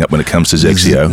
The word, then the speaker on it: XXIO: XXIO. So that's pronounced Zexio. – Did you make that up? The Zexio. Pronunciation, or that's how they say it? – No, that's how they say it.